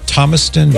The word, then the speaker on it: Thomaston: Do I have it right? Thomaston? Yes. Did I say it right? – Yes, you do.